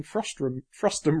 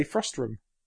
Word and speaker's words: frustum: Well, say the big thing I learned yesterday was that we've all been pronouncing frustum wrong. See? – Frustum. – I say frustrum. – It's frustum culling.